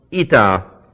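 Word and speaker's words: ita (ήτα): The next one is a long E, and it's pronounced ita. E as in easy.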